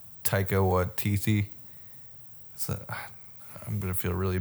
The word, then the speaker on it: Taika Waititi: Waititi.